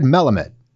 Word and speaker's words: Melamed: And I didn't ask you how to pronounce it right. It's Fred Melamed. – Exactly right.